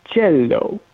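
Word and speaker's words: Cielo: I learned how to say it. Cello, which is a sky in Italian.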